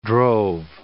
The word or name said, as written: drove